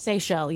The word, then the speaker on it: Seychelles: Have you ever heard of the, I believe it's pronounced, is it pronounced Seychelles? – Seychelles, yeah. – The Seychelles? – Yeah. – The islands? – S-E-Y-C-H-E-L-L-E.